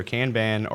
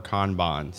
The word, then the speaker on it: Kanban: the authority that I know, at least, that it's either Kanban or Kanban. So I generally refer to it as Kanban, but I believe those are supposed to be both correct pronunciations.